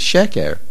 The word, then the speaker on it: sheker: You cannot put a main stress under a chat of segol. So MS is sheker. Sheker, which I did pronounce correctly, that is MS. Because MS is the correct pronunciation.